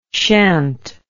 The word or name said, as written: shant